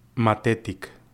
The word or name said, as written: Matetic